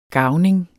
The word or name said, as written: garvning